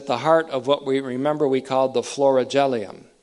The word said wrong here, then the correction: florilegium: or make that the florilegium. I mispronounced that earlier, and I'm doing it again. The heart of the florilegium, that's F-L-O-R-I-L-E-G-I-U-M, you'll see it in print.